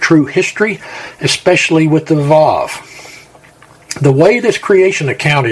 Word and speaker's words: Vav: Vav. It is V in the original text, as shown as the first letter of each verse, and is pronounced V as in vine.